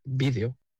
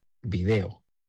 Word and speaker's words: vídeo: Y vídeo es una palabra que utilizo mucho en mis clases, siempre con mis clases a cuestas, para explicar la diferencia entre palabras esdrújulas, vídeo, como lo pronunciamos en España, y palabras llanas, video, como lo pronuncian en Latinoamérica.